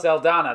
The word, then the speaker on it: Saldana: I was thinking Zoe Zendella. – Who? – Or Zoe Saldana? – Saldana, that's how you say it, yeah.